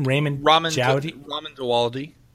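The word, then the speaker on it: Djawadi: Raman Dualdi. – Okay, Raman Dualdi, who's responsible for Game of Thrones.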